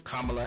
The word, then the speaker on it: Kamala: I listen to some of our colleagues, especially when they call her Kamala. and Kamala. No, her name is Kamala Harris.